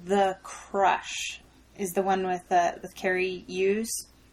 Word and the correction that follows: Elwes: Elwes. – Elwes.